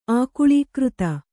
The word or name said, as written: ಆಕುಳೀಕೃತ